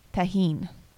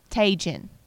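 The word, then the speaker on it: Tajín: There is a special seasoning sauce, and it's called tahine. Okay? It's spelled, if you're from Alabama, you would read it like it says Tajin. All right.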